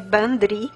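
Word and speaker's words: bandrui: Tem uma palavra celta para isso, que é bandri. Ban é um prefixo feminino do, do gaélico, né? E esse dri se escreve drui, né? Como a gente lê a palavra druida.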